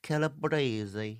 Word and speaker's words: Calabrese: Calabrese.